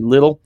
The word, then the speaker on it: Lyttle: I think it's pronounced Lytle. L-Y-T-T-L-E, maybe Little.